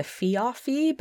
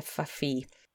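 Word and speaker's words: feoffee: I found out how to pronounce that. I said it before as a fiafi, but it's actually a fafi.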